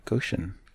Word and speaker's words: Goshen: Goshen.